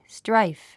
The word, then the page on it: strife: ストライフ